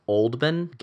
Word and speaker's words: Oldman: It's pronounced Oldman, Gary Oldman.